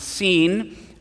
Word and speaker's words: Sin: I wanted to say that I used to pronounce it the wilderness of sin, and I would always wonder, why did they bring him to the wilderness of sin? But it's not the same word as sin. It's pronounced seen,